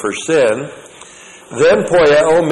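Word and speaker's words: Poieo (ποιέω): And then, with the reference to Jesus Christ, who is our perfect example, we have the verb poieo. P-O-I-E long O. Poieo.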